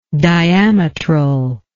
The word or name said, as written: diametrally